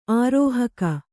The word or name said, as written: ಆರೋಹಕ